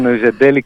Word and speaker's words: Noisedelic: Όχι, πολύ σωστά και είναι Noisedelic Folk με Z. Οπότε και η προφορά ήταν λίγο παχιά στο σίγμα.